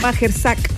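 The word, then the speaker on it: Majchrzak: Que se llama Kamil Majersak. – Majersak. Exacto.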